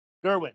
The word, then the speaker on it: Gerwig: But I think it's also interesting that, you know, she's finally Greta, and how do you pronounce her name? – Gerwig, yeah.